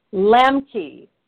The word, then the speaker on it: Lambke: Lambke.